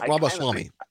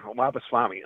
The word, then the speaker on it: Ramaswamy: Viva Ramaswamy. – Yeah, Ramaswamy.